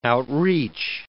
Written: outreach

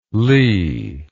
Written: li

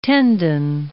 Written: tendon

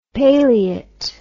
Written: paleate

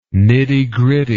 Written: nittygritty

